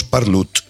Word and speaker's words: parlo: Jo parlo català, però en Girona decimos parlut, como una U y una T.